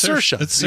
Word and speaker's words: Saoirse: No, it's Saoirse. You pronounce it like inertia, and she told us how to pronounce it like inertia on SNL when she was the host.